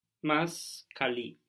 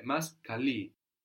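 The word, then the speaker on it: Mas cali (μας καλεί): Mas cali. – Good, and you're keeping the accent on the end there. Mas kali.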